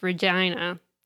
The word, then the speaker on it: Regina: So in Canada, the province is pronounced Regina.